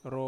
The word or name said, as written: ro